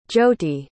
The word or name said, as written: jyoti